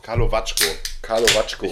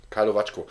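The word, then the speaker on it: Karlovačko: Jetzt trinken wir noch einen Kalavako. – Kalavacko. Ich war mal in Kroatien, habe mich gefragt, wie spricht man das aus und dann habe ich es mir gemerkt. – Kalavacko.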